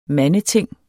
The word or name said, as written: mandeting